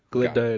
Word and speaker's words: Glinda: Yeah, Glinda and Elphaba, yeah.